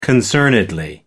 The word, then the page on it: concernedly: American English